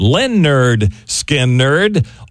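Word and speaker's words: Lynyrd Skynyrd: And we're going back to the anniversary of the release of pronounced Lynn Nerd, Skin Nerd, August the 13th in 1973.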